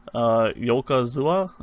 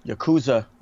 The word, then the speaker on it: Yakuza: Yokozua. Did I say that right? – Yakuza.